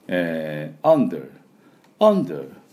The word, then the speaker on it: under: イギリスではそこまで引きませんので、 Under、 Under、 Under みたいな感じで、 Der と最後巻いていきません。